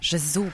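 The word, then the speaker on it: Gesù: Gesu, proszę zwrócić uwagę też na akcent.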